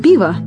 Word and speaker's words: пиво: Пиво.